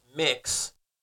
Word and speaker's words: Mx: There is a kindergarten teacher explains how she teaches children about pronouns and gender identity, and she refers to herself as MX and pronounces it mix instead of miss.